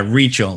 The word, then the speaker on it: Rietschel: Yeah, Rietschel. Like you're reaching for something.